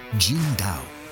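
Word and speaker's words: Tsingtao: Oh, y se pronuncia Jin Dao.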